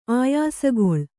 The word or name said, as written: ಆಯಾಸಗೊಳ್